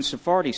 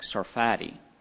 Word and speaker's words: Sarfati: And Jonathan Sarfati says on this, he says, I'm sorry, I said his name wrong, Sarfati.